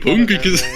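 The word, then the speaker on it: Heynckes: Yep, Hunkes has been reappointed. That's how you pronounce it, isn't it? No, go on. Pronounce it, please. – Jupp Hunkes. – You actually sound like you've nailed it for me.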